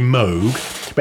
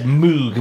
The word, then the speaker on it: Moog: I think the American pronunciation appears to be Moog,